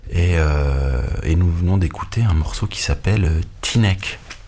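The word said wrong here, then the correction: Teaneck: Je sais pas si c'est bien prononcé comme ça, mais... – Comment? – T-neck? – T-neck! Le T-neck. T-neck. Une chanson de Cannonball Adderley qui s'appelle T-neck.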